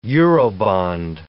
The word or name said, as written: Eurobond